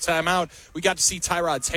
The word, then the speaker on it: Tyrod: By the way, that pronunciation has changed again. I've been told emphatically by Packers PR that it's Tyrod Taylor.